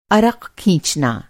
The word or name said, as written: अर्क़